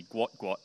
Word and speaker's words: Gout Gout: It's spelt Gout Gout. He's from African heritage. His dad has apparently come out and said it's actually Gwot Gwot, but they spelt it wrong when they were learning the language.